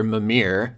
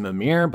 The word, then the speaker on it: Mimir: So that, I believe it's pronounced Mimir, but I could be wrong.